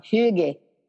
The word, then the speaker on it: hygge: Hygge. H-Y-G-G-E. – But H-Y-G-G-E.